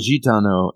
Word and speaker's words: Gitano: And I'm told that's how it's pronounced, the Gitano.